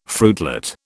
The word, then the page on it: fruitlet: British English